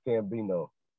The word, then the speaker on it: Cambino: But it's actually just pronounced Cambino. You know, a lot of people, they'd be like CC Cambino. I guess they thought I was doing like the X, you know, the X thing or something, you know.